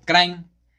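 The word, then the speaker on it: Kranj: Por ejemplo, Krain, nosotros lo podemos escuchar y si nos piden escribirlo, lo podemos escribir tal cual como C-R-A-I-N. Eh, pero ellos, por ejemplo, la manera correcta de escribirlo se escribe crank con K y con J al final. Y esto es con la mayoría de las palabras que terminan así con J.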